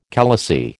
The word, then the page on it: khalasi: American English